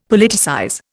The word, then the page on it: Politicize: Indian